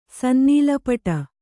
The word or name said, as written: ಸನ್ನೀಲಪಟ